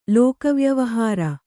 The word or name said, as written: ಲೋಕವ್ಯವಹಾರ